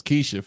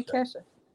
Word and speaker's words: Kesha: I thought it was Keisha for some reason. – nah, her name Kesha.